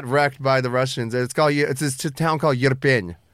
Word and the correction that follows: Irpin: It's spelled I-R-P-I-N. – Irpin. – Irpin. Irpin. Yeah.